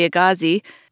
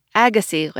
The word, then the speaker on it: Agassiz: Like, I used to say Louis Agassiz, that racist eugenicist that I write about in here, but it's actually Louis Agassiz, like the tennis player.